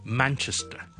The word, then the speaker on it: Manchester: 另外一个大城市咧，亦都系一个我哋好多人都读得麻麻地嘅一个音咧，就系咧曼彻斯特啊，我哋系读 Manchester。 Manchester. The stress is on the first syllable.